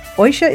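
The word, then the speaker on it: Oidhche: Oisha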